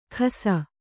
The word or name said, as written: ಖಸ